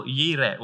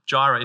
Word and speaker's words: Jireh: And Abraham is relieved at the provision of God, and so he names that place El Yireh, or Jireh, if you want to pronounce the J.